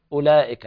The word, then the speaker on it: أولئك: أولئك، إذن نقول ضم الهمزة ولام بعدها، الواو هنا لا تنطق.